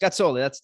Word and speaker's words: Gazzoli: Gazzoli, that's correct.